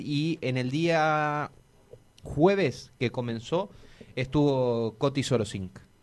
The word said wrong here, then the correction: Sorokin: Sorokin. Ahí lo dije bien.